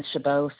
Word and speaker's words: Chabot: Last name is Chabot.